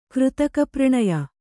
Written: ಕೃತಕಪ್ರಣಯ

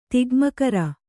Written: ತಿಗ್ಮಕರ

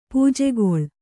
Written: ಪೂಜೆಗೊಳ್

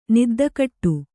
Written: ನಿದ್ದಕಟ್ಟು

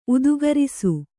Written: ಉದ್ಗಾರಿಸು

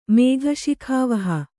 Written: ಮೇಘಶಿಖಾವಹ

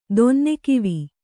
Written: ದೊನ್ನೆಕಿವಿ

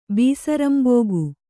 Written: ಬೀಸರಂಬೊಗು

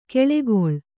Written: ಕೆಳೆಗೊಳ್

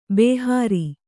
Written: ಬೇಹರ

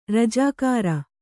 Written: ರಾಜಗೀರೆ

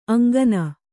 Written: ಅಂಗನ

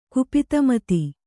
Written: ಕುಪಿತಮತಿ

